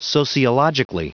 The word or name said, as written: sociologically